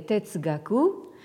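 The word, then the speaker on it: Tetsugaku (哲学): Alors donc vous avez ce composé qui se prononce en japonais Tetsugaku euh, et en chinois Zhe hein, et euh, qui signifie littéralement donc Xue, euh, l'étude de Zhe, c'est-à-dire donc de la sagesse.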